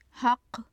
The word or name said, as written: haqq